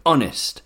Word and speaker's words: honest: Honest. Air. Maybe now you remember the question. Oh yes, which words apart from our begin with a silent H in English? Only these three.